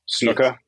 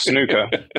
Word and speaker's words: snooker: Often over here, it's pronounced snooker, which is incorrect. – Okay. Yeah. Glad to know that. – It's definitely... – I was about to correct you. – Yep. It's snooker.